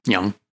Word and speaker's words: Jung: Jung. I don't know how to say his name. – Jung.